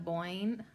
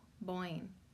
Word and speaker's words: Boin: Neda, N-E-D-A. And her last name's B-O-I-N. So, like, yeah, I guess like coin, but with a B. Boyne. Coin. Boyne. Boyne, Neda Boyne. Neda Boyne, isn't that a fun name? N-E-D-A Boyne, B-O-I-N.